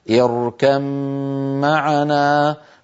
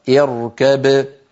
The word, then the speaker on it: اركب معنا: كذلك في قوله تعالى في إدغام الباء في الميم وهو الحالة السابعة، حفص من طريق الشاطبية في قوله تعالى اركب معنا يدغم الباء في الميم ويصير النطق بميم المشددة، هكذا اركم معنا، فإذا وقف بين الباء وأظهرها وقلقلها اركب.